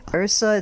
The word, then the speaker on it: Yrsa: Yeah, this is an Icelandic name, Yrsa.